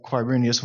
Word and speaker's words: Quirinius: I know that you all pronounced that Quirinius when we were reading it, so you're good.